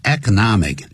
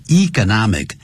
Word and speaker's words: economic: 由于什么关系呢 ?Because of the economic, 或是 economic, 两个发音都可以 ,economic, economic, 都可以就经济方面的就是这个挫败 ,a recession 就倒退了，也就是经济不景气了等等，就这么说。